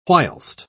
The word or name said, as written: whilst